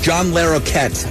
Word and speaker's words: John Larroquette: John Larroquette.